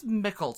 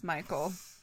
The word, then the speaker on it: Mikal: It's M-I-K-A-L-E or something stupid. – Close. – Ah! – M-I-K-A-L. – Ah! Oh, my stupid! That says Mickle to me. – But it's pronounced Michael. On all the documentaries I have watched, they all said Michael.